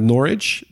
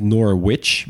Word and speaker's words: Norwich: Eh, we beginnen inderdaad met Norwich. Dus inderdaad niet Norwich, behalve als je iedereen boos wil maken.